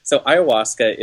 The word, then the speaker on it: ayahuasca: I want to know about your experience with ayahuasca.